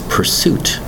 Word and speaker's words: pursuit: Today's word is pursuit. Pursuit. Two distinct syllables. That's right, and also has two U's. It's spelled P U R S U I T.